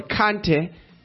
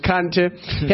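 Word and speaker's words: Kanté: In Western Africa, the sorcerer ruler Samanguru Kant, or Kante, I'm pronouncing that in my language, Kante, has been raiding and conquering people.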